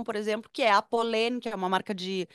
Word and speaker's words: Polène: P-O-L-E com acento aberto, N-E, Polene.